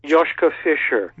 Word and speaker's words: Joschka Fischer: And as you pointed out in your very, very interesting and informative article, many years ago, I will pronounce this wrong, Joschka Fischer, is it? – Joschka Fischer.